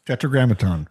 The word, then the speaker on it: Tetragrammaton: Tetragrammaton.